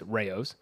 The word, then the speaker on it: Rao's: The RAO, Rouse. – That one's compliant. You're pretty compliant with most diets. – Now you guys don't want to talk about how to pronounce it. It's Rao's.